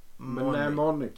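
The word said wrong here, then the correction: Mnemonic: Mnemonik. – Mnemonik.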